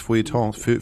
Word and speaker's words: Feuilleton: Aber ihr seid so Lieblinge des, wie heißt das, Feuilletons? – Ja, genau.